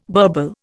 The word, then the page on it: burble: Indian